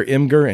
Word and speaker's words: Imgur: like it's like it's for for the internet savvy for the people who like their their Imgur, and yes, that's how it's pronounced on this podcast.